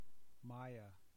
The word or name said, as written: maia